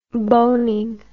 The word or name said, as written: boning